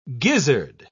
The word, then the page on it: gizzard: British English